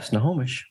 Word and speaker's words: Snohomish: Snohomish, did I say it right? – Yeah, Snohomish.